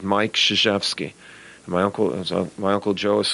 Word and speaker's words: Krzyzewski: I hate the when people say Krasuski. Like it sounds like I'm a zookeeper in some crazy island. But I've heard them all, and that's why a lot of people say Coach K. But you know what? More people have said and pronounced Krzyzewski the right way than I ever imagined.